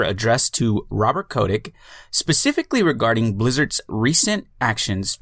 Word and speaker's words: Kotick: Five bipartisan members of the United States Congress have sent Activision Blizzard CEO Bobby Kotick. Am I pronouncing that correctly? – Yeah, Bobby Kotick.